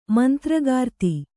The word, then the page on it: ಮಂತ್ರಕರ್ತ: mantra karta